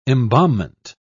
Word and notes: embalmment: British English

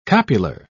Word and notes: copular: British English

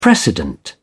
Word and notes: precedent: British English

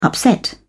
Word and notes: upset: British English

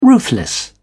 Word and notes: ruthless: British English

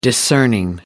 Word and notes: discerning: American English

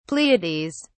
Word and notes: pleiades: British English

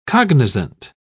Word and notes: cognizant: British English

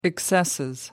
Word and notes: excesses: British English

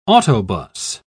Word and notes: autobus: British English

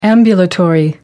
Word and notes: ambulatory: American English

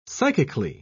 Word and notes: psychically: British English

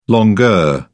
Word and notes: longueur: British English

